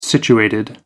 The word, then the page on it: situated: American English